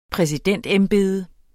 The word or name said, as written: præsidentembede